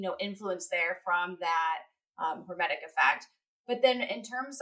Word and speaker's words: hormetic: And so there are cases where with intermittent fasting, because of the hormetic effect, I said it properly, there we go. Because of the hormetic effect, sometimes you can see actually an improvement with intermittent fasting in their car.